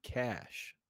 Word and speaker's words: cache: It's C-A-C-H-E. – Yeah. – Spelled it wrong like three times. – C-A-C-H-E, cash. – C-A-C-H-E, cash. – Yeah, that's how you say it.